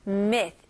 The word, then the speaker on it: myth: 여기서는 myth, 에 발음, I 발음으로 생각하시고요. 마지막 TH 발음. 혹시 못하시면 이거는 살짝 메롱으로 생각하시면 돼요. 자, 보세요. myth. 자, 살짝 메롱이라고 설명하는 이유는 제가 발음할 때는 혀가 살짝 보이는 거예요. 완전히 이렇게, 이렇게까지는 할 필요는 없고요. 이렇게. 오케이? 그리고 여기서 TH 발음은 발음이 솔솔솔솔 이렇게 나가는 느낌이 나야 됩니다.